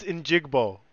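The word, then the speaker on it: Njigba: Njigbo. – I don't like you saying that. – Yeah, I'm the name guy. I'm the name guy. It's Njigbo. What do you want me to say? – You need better diction.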